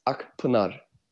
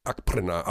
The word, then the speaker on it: Akpinar: Wir sprechen dich, Ismet, seit Jahren Ismet Akpina aus, aber wir haben irgendwann mal gelernt, dass das gar nicht die richtige Aussprache ist. Richtig für deinen Nachnamen? Ismet Akpinar. Ja, genau. Also es, äh, wir haben einen türkischen Kollegen, der sagte, der heißt Akprener.